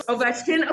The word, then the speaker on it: Ovechkin: I already know it, but I think it's pronounced Ovechkin. – Ovechkin, you got you're close. – Ovechkin. Okay, okay, you got me. Ovechkin.